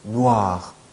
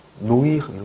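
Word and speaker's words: noir: 오 더하기 2가 있어요. 그러면 오이라고 발음하고 싶죠. 그런데 오이 하면 안 돼요. 우아, 우아. 그래서 누아르, 네. 누아르, 네. 누아르 있잖아요. 한국말로도 많이 쓰이는 누아르. 이게 누아가요. 누아르, 누아르. 아시겠죠? 네. 노이르 이런 거 아니에요.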